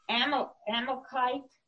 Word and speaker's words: Amalekite: It happened that when David and his men arrived in Ziklag on the third day, that the Amalekite, is how would you say it? – That sounds great.